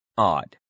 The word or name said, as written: odd